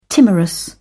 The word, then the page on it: timorous: British English